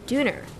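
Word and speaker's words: döner: Josh's doner, which he pronounces duner, is bursting with ingredients.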